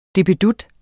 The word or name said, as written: DIPPEDUTTEN